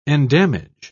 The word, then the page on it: endamage: British English